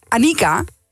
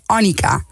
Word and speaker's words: Annika: Nou, het is Annika in plaats van Annika. – Oh ja. Dus de klemtoon op de i.